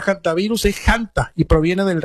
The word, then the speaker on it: hantavirus: Se podría pronunciar también con la H muda, por supuesto. Y también es válida la pronunciación haciendo el énfasis en la primera sílaba con la letra J de Hanta, aunque también es válida la forma antivirus.